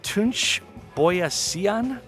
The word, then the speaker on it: Tunçboyacıyan: I don't think that's how you say it. Tunçboyacıyan, I think that's how you pronounce it. T-U-N-C with a little tail, B-O-Y-A-C-I-Y-A-N.